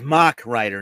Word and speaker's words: Mach Rider: Coming up in number nine is MASH Rider. – It's Mach Rider, not MASH.